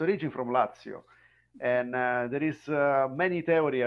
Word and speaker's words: Lazio: So, you will pronounce the place Lazio or Lazio? – Lazio.